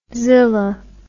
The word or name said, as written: zila